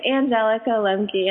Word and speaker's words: Angelica Lemke: It's Angelica Lemke, and thank you for having me.